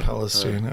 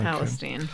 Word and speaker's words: Palestine: Did you double check the pronunciation? – Yes, I did. – Palestine. – Okay. Palestine.